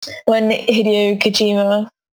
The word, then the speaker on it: Hideo Kojima: And when Hideo Kojima, is that how you pronounce his name? – Yeah.